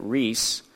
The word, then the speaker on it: Rhys: As I understand it, the first name is Rhys. That's how you would say that, Rhys.